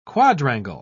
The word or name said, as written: quadrangle